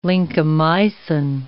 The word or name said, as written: lincomycin